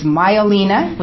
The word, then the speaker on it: Maialina: And Sangria's owners are getting ready to reopen a second restaurant, and I have it phonetically spelled here. It's Maialina, which means little pig in Italian, I'm told, and that's going to be at 6th and Main, opening this spring.